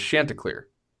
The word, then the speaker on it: Chanticleer: It's not Chanticleer. Apparently, it's Chanticleer.